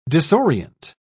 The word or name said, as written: disorient